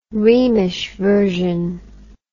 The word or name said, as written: rhemish version